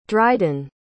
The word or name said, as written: dryden